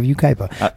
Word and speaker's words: Yucaipa: It's spelled Y-U-C-A-I-P-A. So I was like, I had to relook at that when I was reading this earlier. I was like, oh, no, that's Yucaipa.